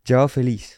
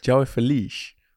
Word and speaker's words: João Félix: Hoe zei je dat? – João Félix. – João Félix. Vind ik mooi uitgesproken.